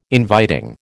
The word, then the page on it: inviting: American English